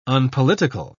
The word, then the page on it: unpolitical: British English